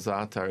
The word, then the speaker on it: za'atar: Za'atar, is that how it's pronounced? Z-A-A-T-A-R.